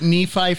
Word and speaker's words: Nephi: I had to actually put Nephi phonetically, so I'm like K-N-E-E-F-I-E.